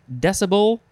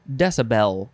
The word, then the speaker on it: decibel: It is, you can pronounce it either decibel or decibel. And I say decibel, but the first pronunciation here is decibel. And I don't think I've really heard that so much. And it's not like the ring-a-ding-ding bell because there's only one L. It's D-E-C-I-B-E-L.